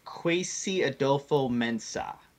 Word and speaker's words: Kwesi Adofo-Mensah: It's Kwesi Adofo-Mensah is how you pronounce Brown's Vice President of Football Operations.